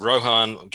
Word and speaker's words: Rohan: And Rohan. Keep saying it wrong. It's like the Lord of the Rings, you know, the Riders of Rohan.